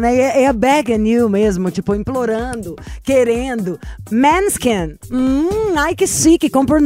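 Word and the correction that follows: Måneskin: Pronúncia, o Maneskin.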